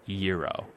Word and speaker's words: gyro: The sandwich, the G-Y-R-O. And in there, they wrote a phonetic pronunciation of the word. And they spelled it all lowercase letters, Y-E-A-R hyphen O-H, Euro. And that's how they said it was pronounced.